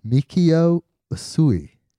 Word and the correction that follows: Mikao Usui: Yeah, you almost got it right. Mikao. – Mikao. – Mikao Usui.